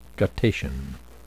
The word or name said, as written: guttation